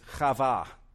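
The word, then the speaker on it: Chava (חוה): The Hebrew name that we pronounce as Eve is Chava, which is from the root word that means life.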